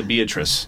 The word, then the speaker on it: Beatrice: Pronounced Beatrice. – That's what I was going to say. Is that how the locals say it, Beatrice? – No, that's how all the out-of-towners say it, but it works.